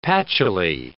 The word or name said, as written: patchily